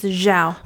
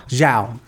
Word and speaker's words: Zhao: How did you say your name correctly? I know it's not Zhao. It's Zhao. – Zhao. Chloe Zhao.